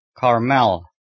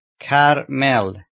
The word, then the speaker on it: Carmel: Should not be pronounced Carmel. It is Lohe Carmel.